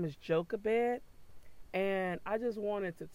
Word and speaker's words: Jochebed: But her name is Jochebed. Now, it is spelled J-O-C-H-E-B-E-D.